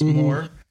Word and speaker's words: Moher: Yeah, it's spelled M-O-H-E-R. – Yeah. – And so Moher is how most people would pronounce it. And that's kind of how it is, but it comes out more as one syllable, so it's Moore.